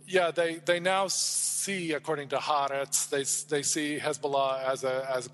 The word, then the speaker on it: Haaretz: I mean, this is Haaretz, which is I don't know if I'm pronouncing that right. – Haaretz, I think, yeah.